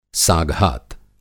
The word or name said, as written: साघात